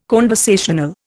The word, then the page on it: conversational: Indian